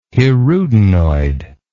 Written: hirudinoid

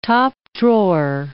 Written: top-drawer

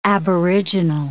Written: aboriginal

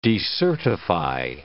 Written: decertify

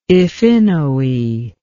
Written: Iphinoe

